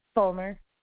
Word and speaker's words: Fulmer: Fulmer, F-U-L-M-E-R. – So that's going to be N-I-C-O-L-E, and then Fulmer, okay. F-U-L-M-E-R? – Yes, Nicole, okay.